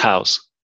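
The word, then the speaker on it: Houzz: So just to clarify the spelling for everyone, it's H-O-U-Z-Z, pronounced mm-hmm. House. Cool. – House, yeah.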